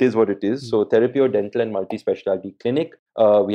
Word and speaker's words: Therapeuo: But it's called Therapio.